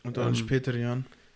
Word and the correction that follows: Young: Young. Ah. Du hast Jan gesagt. Nee, wie, wie der Fußballer Ashley Young. – Ja, ja, aber du hast Jan ausgesprochen. Echt? Ja. Okay. Also, Young.